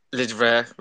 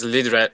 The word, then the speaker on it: Lidre: Yeah, I guess he asked me because, like, Lidre, like, he he asked how to pronounce like is you can pronounce it as lidre like is short version of like live dive repeat